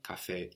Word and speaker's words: café: Le café. Even though it ends with an E, which we've said is generally a mark of a feminine word, although the E on café is accented and always pronounced.